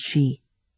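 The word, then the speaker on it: Xi: Nian Bobby Bui, a National Security Council staffer, now assigned to Dirhir, leaned over and said, "Shi Kamyu's his name, spelled X-I, pronounced Shi.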